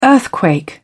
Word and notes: earthquake: British English

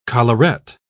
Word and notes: collaret: British English

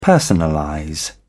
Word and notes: personalize: British English

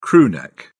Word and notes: crew neck: British English

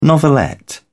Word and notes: novelette: British English

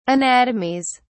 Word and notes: anatomies: British English

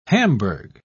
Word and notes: Hamburg: British English

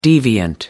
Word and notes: deviant: American English

